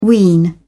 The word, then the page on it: wean: British English